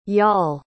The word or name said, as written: yaal